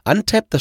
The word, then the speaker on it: Untappd: Untapped, das schreibt man U-N-T-A-P-P-D und zwar könnt ihr damit äh, Bier bewerten und Bier probieren.